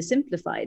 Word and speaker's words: Simplify: Ja, alltså egentligen ska du bara uttala det som att du typ säger Simplify. Det är, liksom, det, det är bara en stavnings... Det är bara stavningen som är lite knasig.